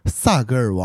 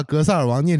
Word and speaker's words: 格萨尔: 江格尔是蒙古族三大英雄史诗之一，另外两部是格萨尔和马纳斯。 – 这因为有一次讲话的时候说蒙古族有很多英雄故事，萨格尔王、江格尔等。 – 哎，为什么江格尔那个？ – 因为他把格萨尔王念成萨格尔王，所以被大家广为流传。